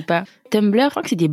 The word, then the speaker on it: Tumblr: Et alors pour toi Du coup, pour moi qui suis poisson, c'était Tumblr. Je sais même pas si je le prononce correctement. Ouais, c'est ça.